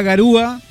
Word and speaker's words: garúa: Bueno, volviendo al tema de la garúa. – Sí. – Es garúa, porque es garúa. – Garúa. – Sí, porque de repente hay mucha. – Con acento en la U.